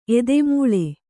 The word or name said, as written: ಏದುಮುಳ್ಳು